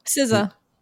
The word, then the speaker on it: SZA: Is it Za? – SZA.